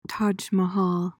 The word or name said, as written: Taj Mahal